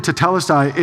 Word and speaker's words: tetelestai: T-E-T-E-L-E-S-T-A-I, tetelestai.